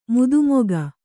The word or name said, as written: ಮದಮುಖ